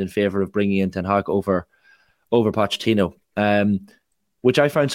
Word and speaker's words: Ten Hag: I have to compliment your pronunciation of Ten Hag as well. You sound like a man who spent some summers in Amsterdam or something. – I was practicing since Simon Cooper came on, because that was the first thing he said to Joe Malloy. He said, Ten Hag, let's get it right.